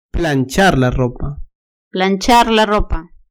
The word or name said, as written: planchar la ropa